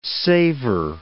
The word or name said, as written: savour